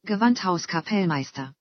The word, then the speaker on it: Gewandhauskapellmeister: Gewandhauskapellmeister.